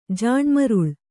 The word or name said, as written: ಜಾಣ್ಮರುಳ